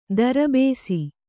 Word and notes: ದರ್ಬೇಸಿ: Kannada; darbēsi

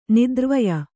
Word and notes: ನೇತ್ರವಾಯು: kannada

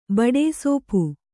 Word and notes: ಬೊಟ್ಟಸೊಪ್ಪು: boṭṭa soppu

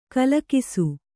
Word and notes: ಗಿಲಿಗಿಸು: giligisu

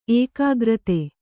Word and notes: ಏಕೀಕೃತ: ēkīkřta

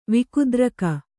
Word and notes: ವಿಕುದ್ರಕ: Kannada

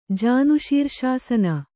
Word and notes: ಜಾನುಶೀರ್ಷಾಸನ: Kannada

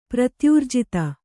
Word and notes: ಪ್ರತ್ಯೂರ್ಜಿತ: kannada

